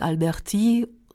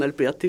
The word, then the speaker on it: Alberti: Den Alberti betont man nicht auf der letzten Silbe.